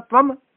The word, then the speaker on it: त्वम: वो ये त्वम है, त्वंग नहीं है, वो अपना गगा बोल दिए त्वंग नमस्त्वंग, त्वंग नहीं है, हाँ संस्कृत है, संस्कृत के हिसाब ना उच्चारण होगा, अपनी मर्जी ना नहीं होगा,